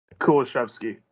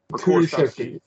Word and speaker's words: Kulusevski: How do you say his name? – Kulusevski. – Kulusevski.